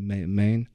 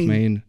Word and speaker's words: Man: On dit Main ou Man, parce que c'est... – Man, parce que tu man, c'est vrai que la prononciation du A, moi je dis Main. – Bah oui, Main. Ça c'est le côté irlandais.